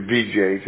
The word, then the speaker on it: Vijay: V I J A Y and you pronounce it VJ, just like it sounds.